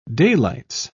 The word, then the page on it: daylights: British English